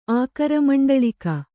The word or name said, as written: ಆಕರಮಂಡಳಿಕ